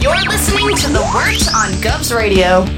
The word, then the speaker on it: Wort: Go over to YouTube, type in The Wort, spelled Wort, pronounced Wort, and type in The Wort and also Mike and Pete, and you'll bring up our YouTube channel.